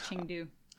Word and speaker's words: Chengdu: Drew Campbell also said, I definitely remember Chengdao in relation to Yohan's origin. – Yes, Chengdu.